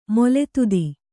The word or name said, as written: ಮೊಲೆದುದಿ